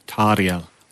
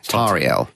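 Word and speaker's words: Tauriel: But who's Toriel? – Tariel. – Sorry? – Tariel. – Tariel. – I got corrected. That is the correct Elvish pronunciation, although it's a new character for the film.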